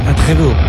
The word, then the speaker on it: après-vous: Supongo yo que se pronuncia après-vous. ¿Será así? Se escribe après-vous. – Après-vous. – Ahí está, te lo dijo, track número 9, Purple, lo nuevo.